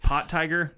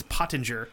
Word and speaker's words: Pottinger: I had to listen to him pronounce it because I was like Pottinger. – I'm like Pot Tiger. No. Yeah. – No, not Pot Tiger. – No, it's Pottinger.